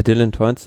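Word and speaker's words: Teuns: Und wie spricht man den aus? Dylan Toins? – Ja, Dylan Toins,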